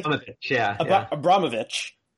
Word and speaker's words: Abramović: forgive me if I'm saying that wrong, you can pronounce it correctly. – Abramović, and Donald Trump.